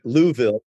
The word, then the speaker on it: Louisville: I learned a long time ago, you have to pronounce it properly. You and I talked about the pronunciation, but Louisville, because I once said to somebody, I'm going to Louisville. And they said, no, you're not. – Yes. Yeah. We, for some reason, I don't know why we pronounce it that way, but it's our Southern way of just swallowing that word.